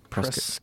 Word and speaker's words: Prescott: Prescott. Say it right. – I'm sorry. – Prescott. Like biscuit. Prescott.